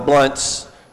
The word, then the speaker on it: Blounts: We appreciate the opportunities to be in the homes of many of you, and appreciate the O'Neills having us over in the Blounts, and or Blunts, but Blounts I know is how we pronounce it here, so I've been working on that all week.